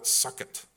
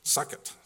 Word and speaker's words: Sukkot: There's a Hebrew word for it, which I can't pronounce properly. And when I bounced it off Gabriella, she said, that's not how you pronounce it. But I said, I'm pretty sure it is. She said, I'm sure they didn't call it Sukkot. But well, why not? Sukkot. S-U-K-K-O-T.